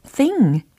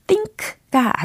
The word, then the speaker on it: thing: thing 이렇게 발음하셔야 되고 think가 아니라는 거 짚어드립니다.